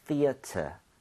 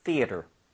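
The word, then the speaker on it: theatre: T-R-E도 가능합니다. 그리고 발음도 심지어 트레가 아니라 ter 맞아요. – 그래요? – 네. 근데 영국식 스타일입니다. – 아. – So, 이거는 theater이라고 발음을 한다면 얘도 네. theater 해도 되지만 만약에 이렇게 스펠링을 한 사람은 조금 영국식으로 가겠다는 느낌이겠죠. – 네. – So R 발음을 빼서 얘는 theater 뭐 이 정도로 발음이 될 텐데 굳이 그렇게 안 해도 됩니다. 그냥 theater 충분히 좋고요.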